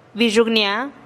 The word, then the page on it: bixugnia: TdVZ